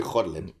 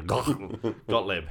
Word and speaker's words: Gottlieb: I'm sure if it's German, it'd be Gottlieb. – Yeah, Gottlieb.